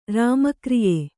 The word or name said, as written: ರಾಮಕ್ರಿಯೆ